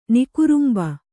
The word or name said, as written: ನಿಕುರುಂಬ